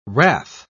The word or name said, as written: wrath